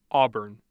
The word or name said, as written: auburn